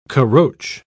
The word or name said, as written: caroche